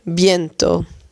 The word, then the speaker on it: viento: Weer een woord met een V. En die V spreek je dus uit als B. Hace viento.